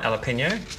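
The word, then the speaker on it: jalapeño: Did I pronounce it right? Jalapeno? – Yep.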